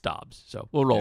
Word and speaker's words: Dobbs: Is it Dubs or Dobbs? – It's Dobbs.